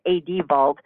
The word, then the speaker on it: ADVault: So at a very fundamental level, my directives and ADVault, it's actually pronounced ADVault, mm-hmm. the A for advance and the D for directives.